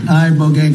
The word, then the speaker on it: Ibogaine: Ibogaine. Remember the name. Is that pronounced relatively properly, what you said? – Yes. – I don't want to get it wrong. Ibogaine, because it's so important, and experienced an 80 to 90% reduction in symptoms of depression and anxiety within 1 month.